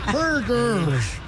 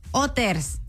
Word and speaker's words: Hooters: Así no se dice. – Entonces, ¿cómo se dice? Dile. – Se dice Hooters. – Hoy no más. – ¿No? Bueno, pues sí, ¿dónde? Porque la H es muda.